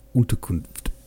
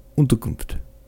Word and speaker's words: Unterkunft: The German for shelter is Unterkunft. How do you say it? The captain asked quickly and doubtfully. – Unterkunft, Pierre repeated.